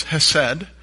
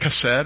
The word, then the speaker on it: hesed: And the Hebrew word that is used there is hesed, Other people say it chesed or chesed. I say it the way it's spelled, just like I don't call Paris Paris.